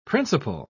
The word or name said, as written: principle